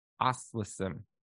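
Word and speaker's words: Oświęcim: So the Polish name of this town is spelled O S W I E C M, Oswiecim. But the way we pronounce it is Auschwitz.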